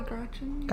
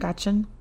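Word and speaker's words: Grachan: Paul and Esther Grashan. I don't know if I'm saying this right. It's G-R-A-C-H-A-N. Grashan? – Probably Grashan. – Grashan.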